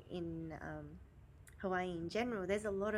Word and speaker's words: Hawaii: Oh, and I learned that the proper way of saying Hawaii is saying the W as a V sound. – Hawaii. – Hawaii.